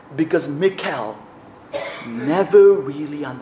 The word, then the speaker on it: Michal: Some people pronounce her name as Michal, but the Hebrew pronunciation is actually Michal.